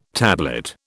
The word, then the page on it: tablet: British English